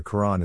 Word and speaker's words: Quran: but the first letter of Quran is not that sound. It's pronounced further back in the throat. The apostrophe is there to indicate a glottal stop between the R and the A.